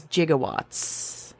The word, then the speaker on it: gigawatts: So NIST says that the G-I-G-A prefix is pronounced correctly, giga. – What? – Giga, giga, giga, giga, giga. – Making giga. – The pronunciation of what we normally would say gigawatts in Back to the Future is actually correct as gigawatts.